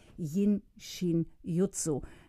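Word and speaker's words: Jin Shin Jyutsu: Bettina Roschewitz ist seit über 30 Jahren Heilpraktikerin und beschäftigt sich mit der japanischen Heilmethode Yin Shin Jutsu. Liebe Bettina, spricht man das so aus? perfekt. Also ich habe sehr viel länger gebraucht, um das so gut aussprechen zu können. Es ist perfekt ausgesprochen.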